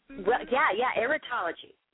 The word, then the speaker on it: erotology: you study what's called pronounce it for me. Erotology, is that? – Erotology. – Erotology is where they pronounce it.